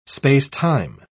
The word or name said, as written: space-time